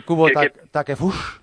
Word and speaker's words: Takefusa Kubo: ¿Se dice bien Kubo Takefush? – Sí, sí, tú pronúncialo exactamente igual que lo leas. Es lo único bueno que tiene el japonés.